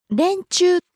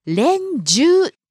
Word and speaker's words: 連中: 連中という読み方と連中という読み方をする人がいるそうです。これはどちらも正しい読み方なんだそうです。 – あ、そうなんですね。 – 連中の方が古いそうですけどね。